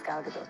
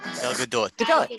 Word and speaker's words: Gal Gadot: But yeah, my name is Gal Gadot. – Gal Gadot.